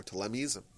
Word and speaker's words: Ptolemies: We're going to be ping ponged back between being controlled by the Seleucids or the Ptolemites or Ptolemies. Properly pronounce it.